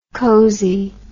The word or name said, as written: cosiness